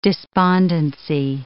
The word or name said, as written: despondency